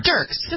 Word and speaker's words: Dierks: Dierks. – Just pretend there's an E there. – What'd you say? – Yeah, just pretend there's none to eat. Dirk. D-I-R-K.